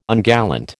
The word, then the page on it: ungallant: American English